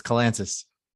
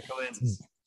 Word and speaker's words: Kalantzis: That's where everybody messes up. It's Kalantzis. – Kalantzis, gotcha. Like Atlantis, right? Okay, with the K. I can do that. – Yeah, Kalantzis.